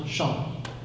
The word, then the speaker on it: Shawano: It's pronounced Shawano. – Yeah. If you pronounce it wrong, like they will yell at you.